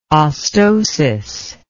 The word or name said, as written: ostosis